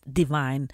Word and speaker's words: DVYN: D-V-Y-N, altså D-V-Y-N, staves navnet, men det udtales Divine.